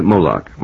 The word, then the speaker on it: Moloch: And I don't think I was ever quite certain in my mind what or who Malak was. – I pronounce it Moloch myself. – Moloch, is it Moloch? – But that comes from the Bible.